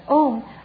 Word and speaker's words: Om: Om, actually almost consists of three syllables, A-U-Ma. A-U-Ma symbolizes the enlightened body, speech and mind.